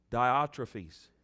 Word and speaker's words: Diotrephes: I wrote unto the church, but Diotrephus, how do you say that? How is it? Diotrephes. Diotrephes, who loveth to be or to have the preeminence.